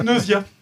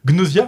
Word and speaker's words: Gnosia: Bref, on va parler de Gnosia. Alors, petit tour du proprio. Gnosia ou Gnosia, pour chez les anglophones, c'est comme ça qu'ils prononcent chez eux.